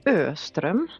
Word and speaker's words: Öström: So my name is Louise Öström. That's actually a Swedish last name and completely impossible to pronounce.